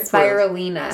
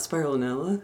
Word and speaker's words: Spirulina: No, how do you say it? – Spirulina. – Spirulina? – Yeah. – It's not Spirulinella.